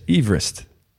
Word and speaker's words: Everest: En hij heet dus Everest. – En dus Mount Everest. – En niet Everest. Dus de achternaam van die gast wordt altijd overal verkeerd uitgesproken.